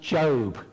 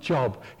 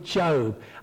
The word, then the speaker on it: Job: A book called, it's pronounced Job, not Job. He's called Job.